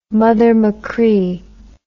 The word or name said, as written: mother machree